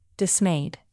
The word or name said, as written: dismayed